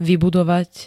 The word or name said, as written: vybudovať